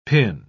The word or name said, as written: pin